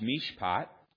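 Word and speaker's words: mishpat (מִשְׁפָּט): The Hebrew word for justice in this verse is pronounced mishpat, literally means to do that which is right and just according to written law.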